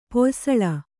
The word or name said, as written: ಪೊಯ್ಸಳ